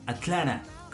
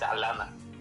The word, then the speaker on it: Atlanta: Yo no sé por qué muchos dicen como Atlanta. Como que, ni, o Atlara, como que ni siquiera pronuncian la T, no sé por qué. – Es que aquí el, la, la, T, la A con la T, es Alana. Los americanos dicen Alana.